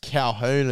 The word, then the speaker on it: Colquhoun: I only just realised it's pronounced Calhoun as well.